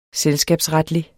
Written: selskabsretlig

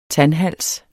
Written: tandhals

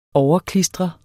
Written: overklistre

